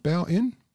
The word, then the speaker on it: Bowen: Is that how you pronounce it? Bowen? – Yes.